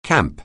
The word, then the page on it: camp: anglais